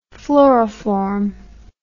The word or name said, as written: fluoroform